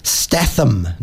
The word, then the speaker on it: Statham: Stratham? Stratham Stath. Statham, not Stratham.